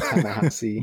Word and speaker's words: Ta-Nehisi: There you go again with these pronunciations, Joe. You're making me look bad. It's Marshawara, Ali, and Ta-Nehisi.